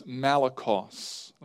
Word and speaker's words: malakos: But the first is malakos. Let me spell that for you. M-A-L-A-K-O-S. M-A-L-A-K-O-S.